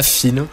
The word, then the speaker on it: Feen: La parole a été donnée à Fine. Je pense que ça se prononce comme ça, F-E-E-N.